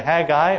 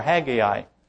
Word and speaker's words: Haggai: And according to Merriam-Webster's dictionary, there's two ways you can pronounce it. You can either say Haggai or Haggai, and they're both acceptable.